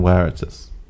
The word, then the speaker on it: Veritas: I believe it's Requitus and Veritas. – It's Veritas? – That's... – Latin? – That's the... Wrong truth. Pronunciation. The V is pronounced with a W sound in Latin.